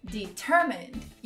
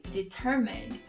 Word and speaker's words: determined: Determinado, determined. You are very determined. I can tell that he's very determined. Is strong and determined. Determined. Não tenha medo de pronunciar cada etapa dessa palavra.